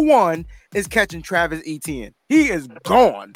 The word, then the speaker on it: Etienne: So Travis Etienne is how you say his name. Etienne, right? – Okay.